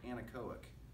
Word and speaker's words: anechoic: It's called the Orfield, I knew I was going to struggle to pronounce this, anechoic. That's how you say it, anechoic. No echo, anechoic room.